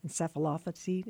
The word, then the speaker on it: encephalopathy: traumatic encephalopathy. Is that, did I say it right? – Yep. – Encephalopathy, CTE.